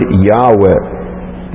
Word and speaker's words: Yahweh: It's the New Testament word for the Old Testament word Yahweh. This Hebrew word, which is made up of four Hebrew letters, which is called the sacred tetragrammaton, which is pronounced Yahweh in the Hebrew.